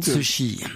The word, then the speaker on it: Cixi: Vous prononcez ça comment, vous ? – Cixi. – Voilà, Cixi. Bah, j'essaierai de faire comme vous à l'avenir.